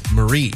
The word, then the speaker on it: Maree: Tom Holland, her husband, I guess calls her by her middle name, Marie. But it's spelled M-A-R-E-E. Marie, right?